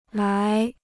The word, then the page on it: 来: lái